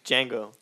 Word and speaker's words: Django: It's Django. The D is silent.